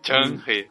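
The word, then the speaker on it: Zheng He: Eu nunca tinha visto ninguém falar a pronúncia correta em. É Chang-He.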